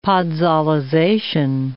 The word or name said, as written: podsolization